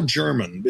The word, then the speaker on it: Jermyn: I said, man, this guy's name is Paul German. It's spelled J-E-R-M-Y-N.